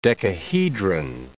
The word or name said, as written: decahedron